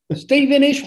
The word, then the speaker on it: Stevenage: Stevenish, Hertfordshire is actually how it's pronounced.